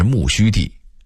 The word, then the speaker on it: 木樨地: 木樨地的读音应该是木樨地，原来此处为养马之地，种有大量的苜蓿，因此得名木樨地，后来改名木樨地等等。